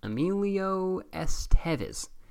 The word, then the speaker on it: Emilio Estevez: Am I saying his name wrong? Emilio Estevez. Oh, yeah, I'm right.